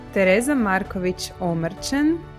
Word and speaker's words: Tereza Marković Omrčen: Ovaj put gošća je naša draga kolumnistica, Tereza Marković Omrčen. Bog, Tereza, ja sam dobro rekla, Bog, izgovorila odlično. Prezime. – Svo troje, odlično.